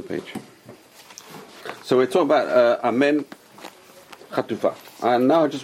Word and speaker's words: amen: It's not amen, it's amen. Right? There's no a sound unless it's followed by yud. Anyway, so what is amen chatufa? Rashi says you pronounce the a very quickly. Amen. Amen. Very quick.